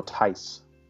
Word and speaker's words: Theis: So, by the way, it's Daniel Theis. – Theis? Okay. Theis. Daniel Theis. – But it's spelled really weird.